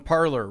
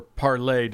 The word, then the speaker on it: Parler: This is the big discussion on Parler, right? Or Parlay, depending on how fancy you want to pronounce it.